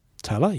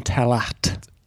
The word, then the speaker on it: Tallaght: It's spelled T A L L A G H T. Now, I know I can't read, but for Jeepers Creepers, how do you get Tala out of that? – Yeah, we would have gone Talat. Talat. We would pronounce that T on the end there, I think.